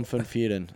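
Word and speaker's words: Van Vuuren: Christian van Vieren is how you pronounce it in Afrikaans.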